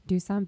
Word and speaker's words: Doosan: You had the Dosan Bears. Dusan Bears. – Doosan Bears.